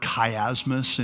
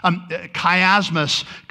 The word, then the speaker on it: chiasmus: Chiasmus comes from the first three letters are C-H-I. And it bugs me to no end because it comes from the Greek letter chi, which you pronounce key. But why do we say chiasmus instead of chiasmus? I don't know. Bugs me a little.